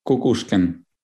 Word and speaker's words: Kukushkin: I'm Sergei Kukushkin.